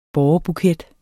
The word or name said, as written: bårebuket